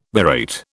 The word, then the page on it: berate: British English